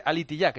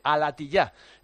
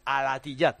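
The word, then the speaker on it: Al-Attiyah: Y leo un mensaje de Rubén Parra, que con su cariño habitual me dice que no se dice alitillá, que se dice alatillá. Es que yo tengo pronunciación de Jail. Entonces, como tengo pronunciación, tiendo a cerrar, pero es alatillá.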